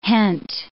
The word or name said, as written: hent